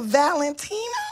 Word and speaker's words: Valentino: It's a Valentino? – Yes.